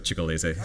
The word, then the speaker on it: Ciccolese: Well, the district court here cited, I don't know if you pronounce it right, Ciccolese. – I used to speak Italian, so Ciccolese.